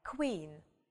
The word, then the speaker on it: queen: Сочетание буквы Q и буквы U всегда читается как ква. Ква. Мы как бы знакомы со звуком к, звук в, да? А это вместе ква. Ну, например, queen или quick, быстро.